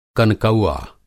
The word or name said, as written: कनकौआ